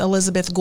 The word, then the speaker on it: Elisabeth: And again, my name's Elizabeth Gould.